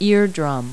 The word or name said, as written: eardrum